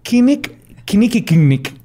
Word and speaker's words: Kinnickinnic: El, 6 de septiembre de 1986 visitó el río Kinik Kinnick Kinnick. Sí, es el Kinnick K-I-N-N-I-C-K-I-N-N-I-C.